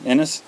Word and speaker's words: Ennis: But Ennis? – Ennis.